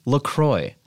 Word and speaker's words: La Croix: Those assholes, if you go on their site, they say it's pronounced La Croix. – Sure. – Well, spell it C-R-O-Y then.